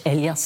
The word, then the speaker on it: Elias: Gillias? Ja, en Anders Elias sagt man.